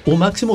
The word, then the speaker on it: UMÁXIMO: Y se pronuncia como U máximo o U máximo. U máximo. – U máximo. Acá alguien ahí diría U máximo, pero aquí es U máximo.